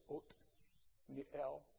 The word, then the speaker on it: Othniel: And there are two pronunciations of what we would say was a T, which is either the hard T or Th. And so the modern Hebrews, the modern Israelis, don't say the soft Th. And so even though it's spelled this way, probably historically, they would say Othniel as opposed to Oth or Oath.